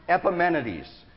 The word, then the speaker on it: Epimenides: Epimenides. Epimenides was a great philosopher. And so they called him to come and to show them what to do to appease the gods. Epimenides came along and he stood among them and he said, okay, this is what I want you to do.